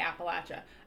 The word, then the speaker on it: Appalachia: So they don't even say the N. – No, all. It's just Appalachia.